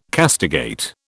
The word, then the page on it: castigate: British English